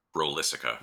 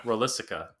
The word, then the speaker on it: Rolissica: And then, in the first draft, Godzilla was going to destroy, how do you pronounce this? Rolissica? – Rolissica. – Rolissica, damn it.